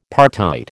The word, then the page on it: partite: American English